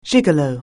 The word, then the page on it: gigolo: anglais